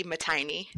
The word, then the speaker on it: Mattaini: My name is pronounced Katie Mattaini. That's like a most common mispronounced name, so no worries there.